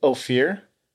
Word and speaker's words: Ophir: Ophir. O-P-H-I-R.